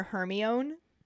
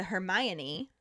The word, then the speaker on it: Hermione: And then he hears Hermione trying to teach Crumb to properly say her name because he keeps calling her Hermione. And she breaks it down to Hermione.